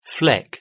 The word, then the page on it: fleck: British English